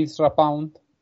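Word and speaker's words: Ezra Pound: sino porque al igual que Isra Pound, no sé si lo he dicho bien, sí, sí, sí.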